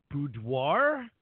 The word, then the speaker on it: boudoir: still get to make it on the show, which is super cool, because today we're going to talk about, and I should know how to pronounce this, boudoir, right? – Good job, yes. – I figured it's like boudoir, something like that, right?